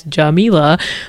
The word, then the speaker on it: Jamila: a DM on Instagram at juh.mee.luh, also known as Jamila, like the pronunciation of my name.